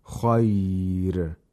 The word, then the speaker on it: خير: Hafs would only pronounce this ra with تَرْقِيق if you stop on the ra. خير. If you stop on the ra, on the word خير, the ra would be pronounced with ترقيق in حفص, but in Warsh, it's always pronounced with ترقيق.